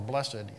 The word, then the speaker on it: blessed: And you know, one component of the word blessed or blessed, you'll probably hear me use the two pronunciations interchangeably this morning, is means happy.